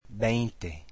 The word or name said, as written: veinte